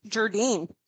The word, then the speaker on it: Gerdin: Gerdin.